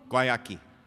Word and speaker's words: Guayaki: Guayaki.